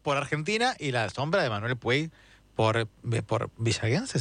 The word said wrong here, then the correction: villeguense: ¿se dice? ¿Cómo se dice? – Villeguense, se dice muy villeguense. – Bien. Lo has dicho muy bien.